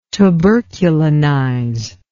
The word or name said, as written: tuberculinize